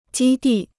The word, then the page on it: 基地: jī dì